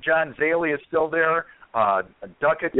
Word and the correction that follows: Duquette: Duquette. It's Duquette.